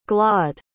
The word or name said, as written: glod